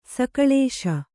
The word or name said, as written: ಸಕಳೇಶ